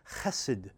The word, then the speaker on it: chesed: That word translated everlasting love in the Hebrew tongue is chesed. If you were to spell it in English, it would be C-H-E-S-E-D.